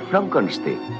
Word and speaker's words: Frankenstein: ¿Doctor Frankenstein? – Frankenstein. ¿Me toma el pelo? No, se pronuncia Frankenstein.